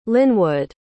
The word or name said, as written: linwood